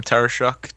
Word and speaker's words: Tarashuk: You want to spell my last name, Christian? – Tarashuk. – Shuk, close enough. – Is that close? – That's close. – How do you spell it? – T's and Thomas, Arashuk. So yeah, you can find us on Twitter, find us on Facebook, all the...